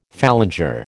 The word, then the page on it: phalanger: American English